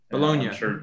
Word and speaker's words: Bologna: Bologna.